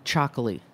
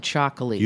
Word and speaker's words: Txakoli: I really like Chocolate. I'm like back into Chocolate. – You have to spell that for everyone. – T-X-A-K-O-L-I.